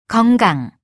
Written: 건강

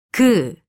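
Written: 그것

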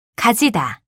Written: 가지다

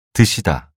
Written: 드시다

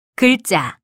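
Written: 기간

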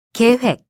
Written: 계획

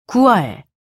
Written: 구월

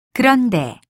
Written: 그런데

